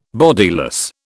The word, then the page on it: bodiless: British English